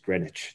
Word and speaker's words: Greenwich: So later I'm visiting and they're like, no, dude, everyone says Greenwich. It's just the way it is.